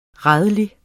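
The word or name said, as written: RÆLIG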